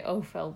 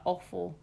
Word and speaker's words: Offill: Yeah, I'm going to say Ophel because a lot of people pronounce her name like awful. But I don't like that. So I'm going to call her Ophel.